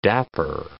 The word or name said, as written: dapper